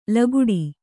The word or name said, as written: ಲಗುಡಿ